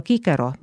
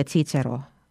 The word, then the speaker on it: Cicero: Antiikaja hääldus C-tähel oli kõikel K, seega Kikero, mitte Cicero, Kaiser, mitte Caesar.